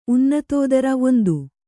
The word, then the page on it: ಉನ್ನತೋದರ: unnatōdara